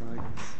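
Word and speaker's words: Eigles: EI is like Eisenhower, so it's Igles.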